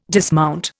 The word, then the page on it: dismount: Indian